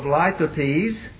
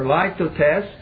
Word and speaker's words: litotes: 2 Kings chapter 7 for our final example of litotes. or Lithotes, depending upon how you want to pronounce it, 2 Kings chapter 7, and notice verse 9.